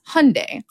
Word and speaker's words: Hyundai: So there was specifically an ad campaign in the US about that brand of car where the tagline was Hyundai, like Sunday. – Well, actually, they were patronizing you because it's actually the Korean Hyundai, which means modernity.